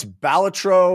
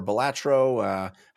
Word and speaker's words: Balatro: Again, that's Balatro or Balatro, however you'd like to pronounce it.